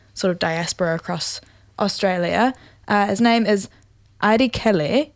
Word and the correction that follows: Airileke: Oh, no, sorry. I butchered that. Airi Leke, I think is how you say that. A-I-R-I-L-E-K-E is how you spell it.